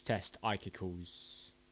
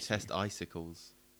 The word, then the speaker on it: Test Icicles: Because the whole point is that it's meant to sound like bollocks. Testicles. It's meant to sound like testicles.